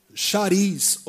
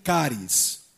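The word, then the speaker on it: caris (χάρις): como chariz ou charis, que na verdade se pronuncia caris, ok? Então, que é justamente né, é a base né, ou a raiz da palavra carisma, ok? Então, caris, não é cari não, tá gente? É caris, ok?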